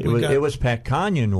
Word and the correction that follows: Cornyn: walked in. Senator Kanyon. – Wow. – Texas. He walked. – Cornyn. – Is that how he pronounces his name? – Yeah, he's an engineer.